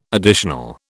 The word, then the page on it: additional: British English